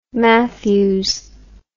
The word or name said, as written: Mathews